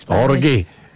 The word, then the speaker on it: Jorge: Yes, Jorge. – Yes. – You know, you have to say it like that because that's how it's pronounced.